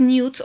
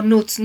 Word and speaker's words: nudes: sobre las famosas nudes o nudes. Nudes es la pronunciación británica, nudes es la pronunciación americana.